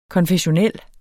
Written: konfessionel

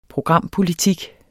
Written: programpolitik

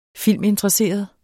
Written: filminteresseret

